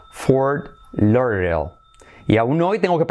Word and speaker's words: Fort Lauderdale: Porque es Lauder, esa D es casi como una R, Lauder, de como R suave, ¿vale? Semiclave, Lauderdale. Y luego la L después, ¿vale? Fort Lauderdale.